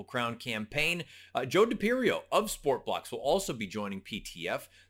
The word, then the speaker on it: SportBLX: Sportblocks, you may be familiar with SportBLX. It's actually pronounced Sportblocks.